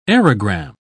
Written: aerogram

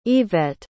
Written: yvet

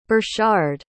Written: Burchard

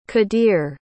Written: qadir

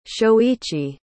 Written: shoichi

